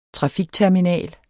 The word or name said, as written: trafikterminal